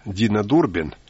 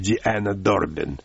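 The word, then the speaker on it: Deanna Durbin: Подобным явлением были хорошо известные в СССР Дина Дурбин, правильное произношение Диана Дурбин, и Джанет Макдоналд.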